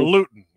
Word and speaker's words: Luton: Luton.